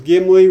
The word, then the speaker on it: Gimli: I think Gimli, correct? Yes. Okay, I'm sorry. I just want to make sure I'm getting the name right. There's a little bit with Gimli when they're in the Mines of Moria, but once again, because he's the dwarf.